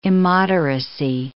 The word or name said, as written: immoderacy